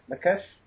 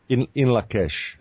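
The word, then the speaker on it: In Lak'ech: How do you say that again? Is it Lakesh? – In Lakesh. – In Lakesh.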